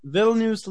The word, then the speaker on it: Vilnius: Vilnius, Lithuania.